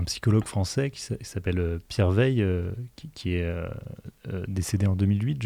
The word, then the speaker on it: Weil: Alors, Pierre Veil, il aurait 100 ans cette année. Donc, au fait, son centenaire. C'est un Alsacien. Il s'écrit W-E-I-L.